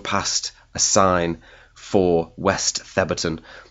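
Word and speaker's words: Thebarton: It's written the Barton, but pronounced Theberton.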